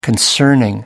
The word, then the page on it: concerning: American English